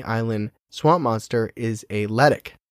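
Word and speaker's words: letiche: It's L-E-T-I-C-H-E. I would have said like Letich or something, but it's okay. Letic.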